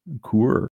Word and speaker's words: coulure: Coeur. C-O-U-L-U-R-E.